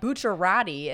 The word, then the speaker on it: Bucciarati: be like Bucciarati, and I'm like, mm-hmm. – it's Bucciarati. I know I'm saying it technically incorrectly, but that's how they say it in the Japanese voiceover.